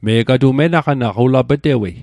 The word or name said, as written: menaqanaq